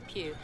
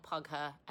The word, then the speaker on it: Pugh: You say it like that, Florence Pugh. It's like Hugh but with a P. No, it's not pug her, and no, it's not poo.